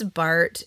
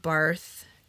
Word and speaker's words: Barth: And it's Barth. It's not Barth, even though it's spelled Barth, because he's German, just so you guys know.